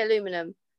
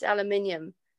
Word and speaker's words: aluminium: You know, like aluminum, I say aluminum, and my kids go, it's not aluminum, it's aluminium. It's like, oh, okay. – Is that how you pronounce it? Aluminium? – Yeah, aluminium is how you say it here in the UK.